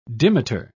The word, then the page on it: dimeter: British English